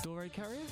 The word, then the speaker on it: Dorée Carrier: And Dorée Carrier.